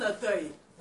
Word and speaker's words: autel: Il y a un autel. – And this altar. – Je prononce ça bien. Autel, là où on fait des sacrifices.